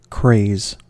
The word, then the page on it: craze: American English